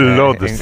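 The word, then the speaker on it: Łódź: Aunque se pronuncia auch, se escribe Lodz, por si alguien lo busca Lodz, en Google. Casi no tiene nada que ver la pronunciación.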